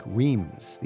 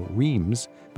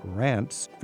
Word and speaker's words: Reims: R-E-I-M-S. This is one of those towns that are hard to pronounce. It sort of rhymes with France. – Yes. – But it's spelled like Reims. The average traveler would look at that and go, Reims, but it's Reims.